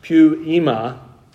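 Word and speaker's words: poiema: It's P-O-I-E-M-A. It's pronounced pu-ema. Probably not in the Greek, but in the South it's pronounced that way.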